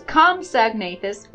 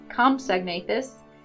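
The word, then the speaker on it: Compsognathus: Consignathus. – Very close. Comsognathus, with a C-O-M, actually silent P, Comsognathus, is a little tiny guy, which is a carnivore, but was about the size of a chicken.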